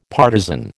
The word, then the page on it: partisan: American English